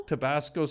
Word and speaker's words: Tabasco: I always thought they looked like tobacco sauce. Or how do you say it? – Tabasco? – Tabasco sauce mixed with beef gravy.